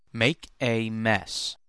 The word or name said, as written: make a mess